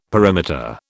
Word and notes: perimeter: British English

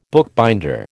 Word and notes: bookbinder: American English

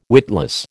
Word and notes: witless: American English

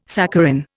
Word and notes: saccharin: Indian

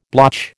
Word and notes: blotch: American English

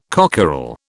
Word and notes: cockerel: British English

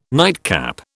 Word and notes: nightcap: British English

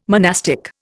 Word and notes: monastic: Indian

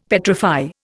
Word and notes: petrify: Indian

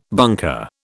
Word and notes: bunker: British English

